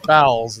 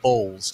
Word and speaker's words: Bowles: Not Bowles, it's Bowles, Todd.